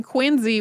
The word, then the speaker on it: Quincy: So if you did not grow up in the Boston area, you may wonder why we're pronouncing the name Quincy with a Z in it when Quincy is Q-U-I-N-C-Y.